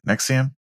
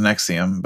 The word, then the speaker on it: NXIVM: That's how you say it, right? Nexium. – Yeah. – Sure as fuck this not spelled that way. Just so everybody knows. Yeah, I don't know why they spell it like that. It really confuses me. There's letters in there that do not make that sound. It's NXIVM. I don't know how that spells Nexium, but I'll take it.